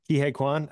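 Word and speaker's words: Ke Huy Quan: Oh man, Ki-Hai Kwan.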